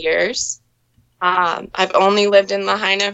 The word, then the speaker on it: Lahaina: You're in the Lahaina.